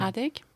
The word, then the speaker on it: Heartache: Comment on prononce, tu m'as dit tout à l'heure? – Heartache.